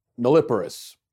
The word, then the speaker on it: nulliparous: They had trouble deciding and then had access problems or They were young and nulliparous. Now, that last cat, I don't even know how to pronounce that. Nulliparous, I think is how you pronounce it.